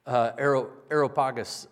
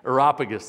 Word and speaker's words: Areopagus: I'm reminded of Paul in Acts 17 when he was at the Areopagus. I just butchered that. I'm not even going to try again. It's not in here today. I had it earlier this week. Areopagus, there we go.